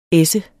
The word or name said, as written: essens